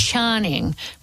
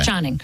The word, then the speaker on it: Tsarchnig: And I've had a bit of a look on one of the other surnames from my family, which is Charning. But it's spelled T-S-A-R-C-H-N-I-G. – Okay. – Charning.